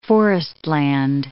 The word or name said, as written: forestland